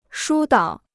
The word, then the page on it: 疏导: shū dǎo